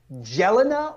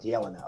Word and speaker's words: Yelena: it Jelena or is the J pronounced a different way? – It's actually Yelena, so think Yelena. – Helena. – Okay, so it's with a Y, sort of a Y sound, Yelena.